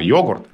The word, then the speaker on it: йогурт: это старая норма, это старое заимствование, а новая норма йогурт. Но тогда ведь э, все вспоминали, что никто в, э, слово с ударением «йогурт» никогда не слышал, то есть поэтому они, оно воспринималось как новое.